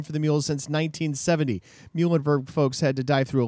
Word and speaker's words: Muhlenberg: Yes, for goodness sake, people. That's how you pronounce Muhlenberg.